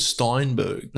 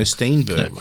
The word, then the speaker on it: Stenberg: I think you said Steinberg. – No, Steenberg.